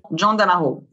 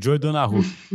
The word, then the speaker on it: John Donahue: Como é que fala o nome dele? John Donahue. John Donahue. eu li o. Eu não me ousei arriscar falar o nome dele aqui, o Joy Donahue.